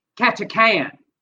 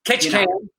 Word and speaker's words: Ketchikan: All right, and by the way, Tot said it's pronounced Ketchikan. – Ketchikan.